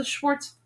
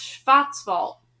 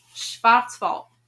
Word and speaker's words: Schwarzwald: It was called the Schwarzwald, the Wood of Darkness. That's Schwarzwald. Schwarzwald. Okay, so I pronounced it wrong at first. That's Schwarzwald, in case you were wondering.